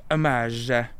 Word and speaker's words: homage: And then where's the sort of the notion of paying homage? Sorry, let's not pronounce the H. Paying homage.